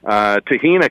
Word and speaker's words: Tahina: Tahina kind of rhymes with Sabrina, too, I guess.